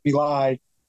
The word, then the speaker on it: Milei: And I think we've decided that Milei is how the last name is pronounced.